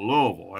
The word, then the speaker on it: Louisville: I grew up in Southern Ohio, so that's why when I pronounce it like somebody from the Appalachian area. I call it Louisville. That's how they pronounce it when you're back there.